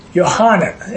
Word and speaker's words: Yohanan: And Yohanan is actually the name. Three syllables instead of one syllable.